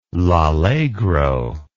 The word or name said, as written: L'Allegro